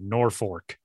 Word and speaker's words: Norfolk: And people in Nebraska pronounce it Norfolk because they say it's at the North Fork of the Platte River, which to me, I don't know, put a second R in there if that's how you want people to pronounce it then.